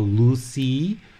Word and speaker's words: Lusi: So in Thai tradition, we call Lusi, or the way that you'll hear it pronounced kind of in India is Rishi or Risi.